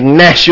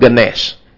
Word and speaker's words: gnash: You gnash your teeth. Uh, and it's a silent G, by the way. It's not ganash.